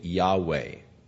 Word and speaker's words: Yahweh: The second, all capital L-O-R-D, indicates that it is a translation of that Hebrew name for God. It is the YHVH tetragrammaton that is unpronounceable, but according to most scholars, the pronunciation is probably Yahweh.